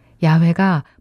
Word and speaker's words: 야훼: 여호와는 야훼의 영어식 발음이고 야훼가 본래의 발음이었을 것이라고 성경학자들은 이야기를 하고 있습니다.